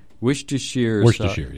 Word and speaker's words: Worcestershire: Worcestershire. – Worcestershire, yes.